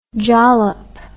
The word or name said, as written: jollop